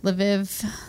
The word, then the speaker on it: Lviv: And then the other is the historic center in the city of Lviv. Is that how you would pronounce that? Maybe. L apostrophe V-I-V. – Yeah, maybe. – Lviv, Lviv.